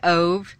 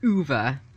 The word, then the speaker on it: Ove: It's A Man Called, and I will say Uwe, but some people say Ove. It's by Frederick Bachman. I actually listened to the audio version of this book, and it was excellent. And they pronounced it Uwe, but it's O-V-E.